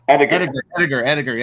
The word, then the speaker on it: Ediger: Ediger. – Yes, sir. There we go. – But it's more commonly pronounced the way you did it.